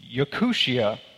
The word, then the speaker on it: Yakutia: Because I was actually talking about Yakutia, I think is how you pronounce it, a region in Russia.